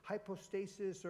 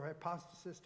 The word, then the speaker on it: hypostasis: The word assurance is hypostasis or hypostasis, depending on how you want to pronounce it in the Greek.